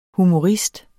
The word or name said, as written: humorist